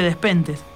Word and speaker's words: Despentes: Se escribe Despentes. Nosotros acá en Argentina decimos Despentes.